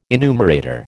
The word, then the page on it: enumerator: American English